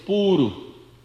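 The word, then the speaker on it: Pūru: Actually it is not Puru, it is Puru with the long U.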